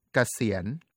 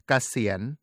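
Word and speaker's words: เกษียณ: Kasien is spelled with Sara E, Gokai, Sorusi, Sara E,ยอยักษ์ and นอนเนน Note that สระสี is pronounced like สอเสือ even though they are written differently. The same applies to นอนเนน which is pronounced like นอนหนู when it appears at a final consonant.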